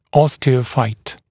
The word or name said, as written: osteophyte